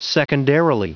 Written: secondarily

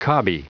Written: cobby

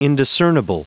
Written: indiscernible

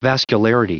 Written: vascularity

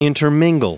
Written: intermingle

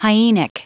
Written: hyenic

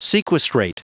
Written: sequestrate

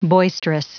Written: boisterous

